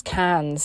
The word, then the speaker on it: Cairns: Uh, I say Cairns, but I think because it's spelled C A I R N S, uh, but everyone says it's cans, so who knows.